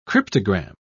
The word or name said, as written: cryptogram